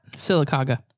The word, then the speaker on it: Sylacauga: Sylacauga.